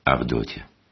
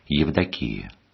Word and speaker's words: Евдокия: Авдотья. Так в простом народе произносили греческое слово «Евдокия», что значит «благоволение». Но такое произношение бывает лишь в селах, а в городах говорят правильно «Евдокия».